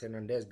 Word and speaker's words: Hernandez: Well, I just mentioned from the commenter that he's right to be pronounced Hernandez because he's French.